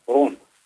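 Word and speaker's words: Rhône: Ren? – Ja, det står Rone, er det Rende? R-H-O-N-E. – Ja, det er Rone.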